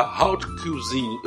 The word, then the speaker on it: haute cuisine: da haute cuisine. Eu não sei como é que pronuncia essa desgraça, mas é alta cuisine. – Haute cuisine. – Haute cuisine. Eu não consigo fazer o bico.